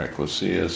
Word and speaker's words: ecclesia: The ecclesia, or ecclesia, some people might pronounce it, the body of Christ.